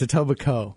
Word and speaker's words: Etobicoke: Well, except that the Coke is silent. It's Etobicoke. – Yeah. Oh, is it? – The K is silent, yes.